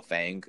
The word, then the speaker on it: FANG: FANG is the pronunciation.